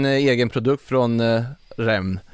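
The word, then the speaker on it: Reims: Eh, men han, han, eh, den unga killen från, eh, vad säger man, Rem. Mm. – Det är så man uttalar det va?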